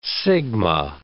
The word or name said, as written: sigma